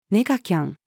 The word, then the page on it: ネガキャン: female